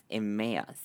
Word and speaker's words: Emmaus: In English, I'm going to see if I can say it right. It's Emmaus.